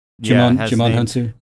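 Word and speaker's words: Djimon Hounsou: Jimon Hansu.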